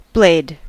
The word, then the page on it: blade: American English